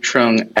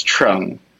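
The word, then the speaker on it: Truong: It should be Luke X Trung. – And that's spelled T-R-U-O-N-G. I'm sorry, I've been pronouncing it wrong this whole interview.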